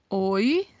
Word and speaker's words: Hoy: Hoy significa hoje, assim como no português o H não tem pronúncia no espanhol.